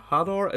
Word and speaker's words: Hadar: And finally, it was announced that CDPR would be working on a new original IP codenamed Hadar. It's spelled H-A-D-A-R. I have no idea how you say it. It's like radar with an H.